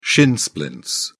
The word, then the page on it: shin splints: British English